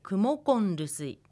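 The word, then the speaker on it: クモコル ルスイ: なのでクモコロルスイは発音するとクモコンルスイというふうに変化します。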